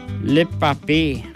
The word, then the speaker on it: le papier: Le papier.